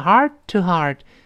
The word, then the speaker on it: heart-to-heart: 那读成 heart-to-heart，第一个 heart 后面那个 t 失去爆破，马上再读 to heart。